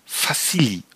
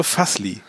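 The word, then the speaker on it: Vasilj: Fassili, oder wie wird der ausgesprochen? Nur Fassli.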